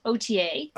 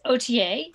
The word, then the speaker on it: Authier: Is that Authier? – So the name of the brand is OTA. – OTA. – And it originated. Yeah, it's OTA.